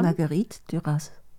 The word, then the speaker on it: Marguerite Duras: Marguerite Duras.